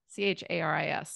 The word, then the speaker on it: Charis: And my name is tricky. You guys have all said it perfectly. It's spelled like the city, spelled and pronounced like the city Paris to help everyone understand, but with a CH, C H A R I S.